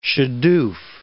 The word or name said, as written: shadoof